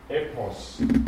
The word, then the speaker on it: Epos: kurzes Epsilon, dann Pi, Omikron Sigma. Betonung über dem Epsilon. Epos.